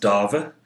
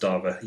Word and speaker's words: Dave: and I think his name is pronounced Dava. It's spelled Dave, D-A-V-E, but I think it's pronounced Dava.